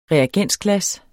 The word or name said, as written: REAGENSGLAS